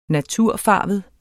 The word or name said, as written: natur